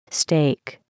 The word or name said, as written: steak